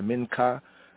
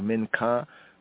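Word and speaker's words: minka (מנחה): And just a little bit more right here on the mincha, Hebraically from the Hebrew, mincha. In this form here, minchat. Others say the minka. Here I and I say the minka, as it's pronounced in modern Hebrew, minka.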